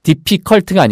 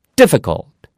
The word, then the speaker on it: difficult: 그래서 C U L T에서 뒤에 2는 안거잖아요 그럼 C U L T가 그 c u l t 예요 그래서 어쨌든 예, 발음이 difficult가 아니고요. D를 강하게 하고 difficult, difficult 이렇게 발음하시면 되겠어요.